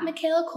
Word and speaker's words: Makayla: So my Instagram's at Mikayla Culpepper. And my name is spelled like how it sounds, M-A-K-A-Y-L-A.